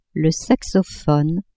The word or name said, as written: saxophone